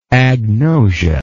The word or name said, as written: agnosic